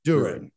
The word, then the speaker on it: Duren: Duren. – Okay. They got two great lottery picks last night. Duren and Ivy.